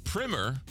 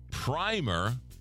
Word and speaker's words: primer: Primer, long I, rhymes with climber, is the first coat of paint. Primer, short I, is a school book.